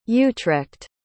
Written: utrecht

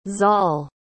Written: zoll

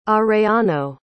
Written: arellano

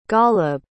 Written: ghaleb

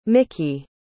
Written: miki

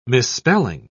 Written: misspelling

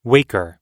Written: waker

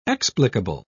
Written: explicable

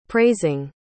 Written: praising

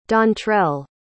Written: dontrell

